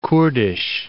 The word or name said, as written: Kurdish